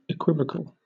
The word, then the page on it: equivocal: British English